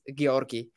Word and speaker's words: Georgi: Yeah, no, I'm from Bulgaria, so the Bulgarian way of pronouncing it is Georgi.